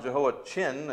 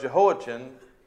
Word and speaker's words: Jehoiachin: Well, Jehoiakim's son, Jehoiachin, if you want to pronounce it chin on the end there, was a successor to his father.